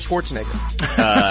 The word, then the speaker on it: Schwarzenegger: Arnold Schwarzenegger. – am I saying that right? – Sure.